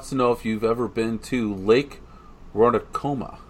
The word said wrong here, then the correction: Ronkonkoma: Did I say it right? Ron Con. Coma? – Ronkonkoma. I don't. – I, I have not. Crazy stuff. I'm gonna. I'm gonna chime in. I'm gonna chime in because that sounds like it's from Long Island, and it's gonna be Ronkonkoma.